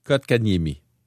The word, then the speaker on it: Kotkaniemi: Oui, Kotkaniemi.